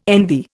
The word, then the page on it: envy: Indian